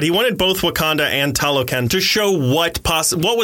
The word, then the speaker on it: Talokan: I don't remember how to pronounce it. Talokan? Sure. Sure is. Talokan.